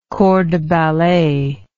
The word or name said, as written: corps de ballet